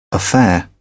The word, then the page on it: affair: British English